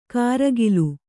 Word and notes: ಕಾರಗಿಲು: kāragilu

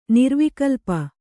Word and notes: ನಿರ್ವಿಕಲ್ಪ: nirvikalpa

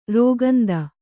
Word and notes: ರಾಕೇಂದು: Kannada

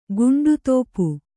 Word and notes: ಗಂಡದೀಪ: gaṇḍa dīpa

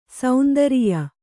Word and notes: ಸೇಂದ್ರಿಯ: sēndriya

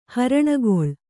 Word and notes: ಹರಣಗೊಳ್: haraṇagoḷ

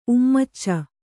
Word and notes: ಉಮ್ಮಚ್ಚ: ummacca

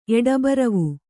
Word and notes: ಎಡಬರವು: eḍabaravu; kannada

